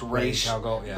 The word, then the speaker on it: Ra's al Ghul: it's Ra's al Ghul in the movie. In the comics, it's actually pronounced Ra's al Ghul.